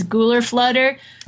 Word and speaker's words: gular flutter: Gular Flutter? Is that the correct pronunciation? – Yeah, that's, pronunciation I know, which I think is maybe right in some sense, is Gular Flutter.